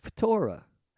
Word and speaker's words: phthora: The original Greek word for corruption is phthora, pronounced phtora.